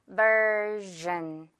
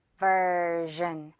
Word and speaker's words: version: 발음은요, version 당연히 아니고요. version, gen, okay, version. 전으로 발음하면서 살짝 좀 불어 같은 느낌도 살짝 넣어서 즈, 즈 이런 발음 넣어주면 좋아요.